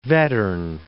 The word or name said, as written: Vattern